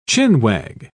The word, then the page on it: chin-wag: British English